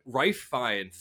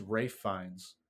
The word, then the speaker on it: Ralph Fiennes: Rife Fiennes is how you pronounce it.